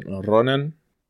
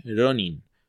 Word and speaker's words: Ronin: Por algún motivo los gringos siempre dicen Ronan. Y por eso Ronan también se dice Ronan. Y a todos los pronuncian Ronan a pesar de que unos son I y otros son con A. Y en realidad la palabra es Ronin.